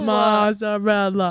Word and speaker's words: mozzarella: There's no T in mozzarella. – It's just how you pronounce it. – M-O-Z-A.